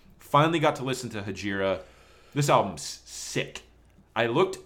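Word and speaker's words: Hejira: I think it's pronounced Hegira. – Yeah, it sounds right.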